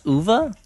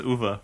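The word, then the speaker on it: Uwe: Yeah, it's pronounced Uva. – I didn't know that. – The video game director guy? – Yeah. – Uva? Really? I always said Uva. – I mean, it is German, so it makes sense that it would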